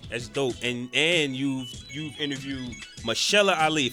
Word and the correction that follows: Mahershala: If I said it wrong, please correct me. Mahershala. – Mahershala.